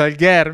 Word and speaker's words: Alguer: Ricordo ancora, scritto Alguer Summer Festival, ma come mi insegna Fulvio si pronuncia Alguer Music Festi Summer Festival, a partire da questa sera con Tommaso Paradiso, poi con tutte le informazioni le trovate sul sito.